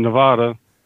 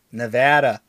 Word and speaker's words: Nevada: Mississippi and Nevada. Did I say that right? – Nope. Nevada.